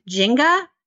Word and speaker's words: Nzinga: And one of the pronunciations for her name is Jenga.